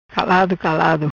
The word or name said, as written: Calado Calado